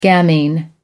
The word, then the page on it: gamine: American English